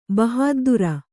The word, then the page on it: ಬಹಾದ್ದೂರ್: bahāddūr